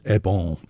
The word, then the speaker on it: Eben: It, the title is Eb. E B E N, it's spelled, uh, which means the other one, and the phonetic uh, transcription of it, it, suggested a nasalization with it, so I think it's Ebon. – I'm guessing it's a song about a disillusioned lover and a parting, as Ebon means the other one in Breton.